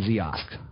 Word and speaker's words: Ziosk: So keep an eye on Lytro, Loku, and Ziosk. It's kiosk spelled with a Z. It's Loco, L-O-C-U, L-O-C-U, and Lytro.